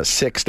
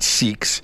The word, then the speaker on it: Sikhs: So I'm told that it's pronounced six now and not seeks, but six to be granted helmet exemption.